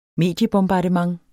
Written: mediebombardement

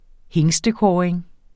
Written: hingstekåring

